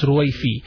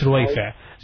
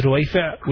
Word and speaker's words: Ruwaifa: Yeah, there is Ruwaifa actually, but the pronunciation of Ruwaifa, the Ayn, some of the people who are not speaking Arabic, they don't pronounce the Ayn, so they pronounce Ruwaifi, while it's Ruwaifa. So the proper is Ruwaifa, which is a good name, and it refers to a person from noble family.